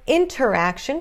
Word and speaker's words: interaction: Aqui de novo, estamos vendo T, NT, numa sílaba fraca, numa sílaba átona. Então, esse T some. Não é interaction, pode ser interaction, isso não é errado, tá? Mas a pronúncia mais comum que você vai ouvir é interaction.